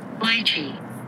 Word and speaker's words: lychee: Lychee. Lychee. – Lychee. – They're both accepted pronunciation. Lychee and lychee.